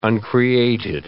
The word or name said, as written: uncreated